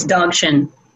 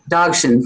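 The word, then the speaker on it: Dzogchen: It's Dogshin. – Dogshin, thank you.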